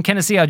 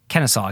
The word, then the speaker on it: Kenosha: Lewis from Kenosha, Georgia. Kenosha, excuse me. I pronounced it wrong again.